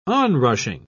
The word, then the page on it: onrushing: British English